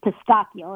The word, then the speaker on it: pistachio: I say pistachio. That's the Italian way to pronounce it.